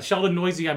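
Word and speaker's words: Sheldon Neuse: Yeah, Sheldon Noisy, I believe is how it's pronounced.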